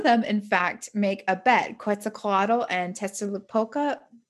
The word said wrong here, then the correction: Tezcatlipoca: Nope. Let me try that again. Tezcatlipoca? No, yeah. I'm definitely putting the emphasis on the wrong syllable. Tezcatlipoca.